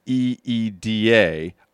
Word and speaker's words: Lida: he spelled it L I D A, but then said pronounced it E E D A.